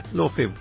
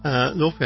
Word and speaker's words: Laufey: I guess, yeah, Lofi, L-A-U-F-E-Y is how it's spelled. Lofi, I said, no, I haven't.